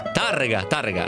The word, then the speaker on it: Tárrega: si chiama Francisco Tárrega o Tárrega? Tárrega, Laura Faggin dice con l'accento sulla prima A, è spagnolo, è nato a Villareale, morto a Barcellona nel 1909 e lui ha inventato esattamente questa musichetta qui.